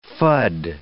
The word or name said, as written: fud